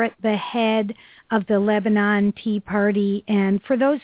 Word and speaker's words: Lebanon: I've been schooled that I don't pronounce it correctly. It's supposed to be Lebanon. But I'm more phonetic than that.